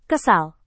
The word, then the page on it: kesal: indonesian